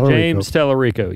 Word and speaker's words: Tallarico: Tallarico, is that how you pronounce it? – James Tallarico, yeah.